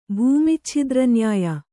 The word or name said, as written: ಭೂಮಿಚ್ಛಿದ್ರನ್ಯಾಯ